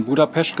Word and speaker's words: Budapest: Du bist in Budapest geboren, wie ich mittlerweile gelernt habe, so spricht man es aus.